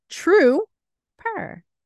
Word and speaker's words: Trouper: Trooper. Spelled T-R-O-U-P-E-R. Pronounced true-per.